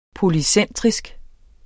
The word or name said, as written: polycentrisk